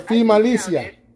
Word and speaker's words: Finalissima: The Finalissima. – Yeah, the Finalissima.